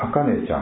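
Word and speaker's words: 秋音: あかねちゃんは今日生まれてですね、いい名前がついておりますね。秋の音と書いてあかねと、ね、とても素晴らしいいい名前だなと私は思うんですね。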